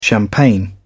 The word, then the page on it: champagne: British English